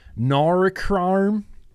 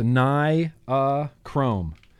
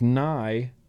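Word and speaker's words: nichrome: No, Breeze Tones, it's actually pronounced Norikrarm. Norikrarm. I'm just giving you a hard time. It's actually pronounced Nye-a-chrome. Like Nye-a-chrome. It's the proper way to pronounce it.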